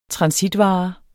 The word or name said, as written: transitvare